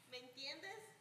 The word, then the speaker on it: ¿Me entiendes?: Did I say it right in Spanish? Me entiendo. Me entiende. Ah, I didn't say it right. Me entiende. Is that right? Okay. They're still not responding. Maybe you should stand up and say it. Say it. Stand up. Say it. ¿Me entiendes? Right.